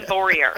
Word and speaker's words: Voyer: It's like warrior with a V, Voyer.